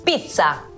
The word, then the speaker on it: pizza: Pizza. Como con T, como si tuviera una T, pizza. – Sucho, ¿vos cómo le decís? ¿Pizza? – Con C.